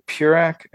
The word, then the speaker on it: Peurach: At Greg Peurach, and the last name is spelled P-E-U-R-A-C-H.